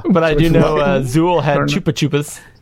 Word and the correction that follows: Chupa Chups: Chupa Chups, Chupa Chups. See, I don't even know how to say it.